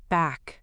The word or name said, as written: back